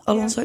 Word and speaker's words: Alonso: Zeg ik het goed? Alonso? Ja, plek 6.